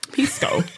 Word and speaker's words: pisco: What's pisco? – Pisco. – No, you really have to like make the emphasis on the I, or else it just sounds like she's drinking pee.